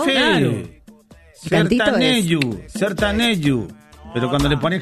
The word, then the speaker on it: sertanejo: Ese tipo de música se llama sertanejo. Se escribe sertanejo. – Sertanejo. – Se pronuncia sertanejo.